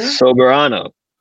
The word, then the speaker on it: Soberano: I said this wrong earlier, Sobernero Jr.? – Soberano. – Close. Soberano. Yeah. Sober, like as in sober as in I don't drink.